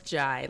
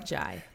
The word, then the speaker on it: Ndiaye: It's pronounced Jai, like it rhymes with pie. Jai.